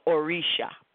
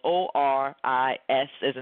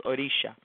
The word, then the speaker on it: Orisha: Orisha is spelled O R I S as in Sam H A, or you can leave out the H and it's Orisa, but it's still pronounced Orisha.